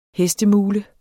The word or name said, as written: hestemule